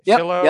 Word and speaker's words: Shiloh: Is that how you say it? Shiloh? – Yep. Oh. Shiloh. Like Shiloh. The dog. Like the dog.